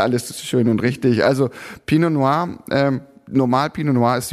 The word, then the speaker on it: Pinot Noir: Der Spätburgunder Pinot Noir von Daniel Twardowski oder sagst du Pinot Noix? Nee, ne? das wird ja schon Pinot Noir ausgesprochen.